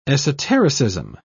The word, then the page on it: esotericism: British English